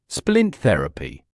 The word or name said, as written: splint therapy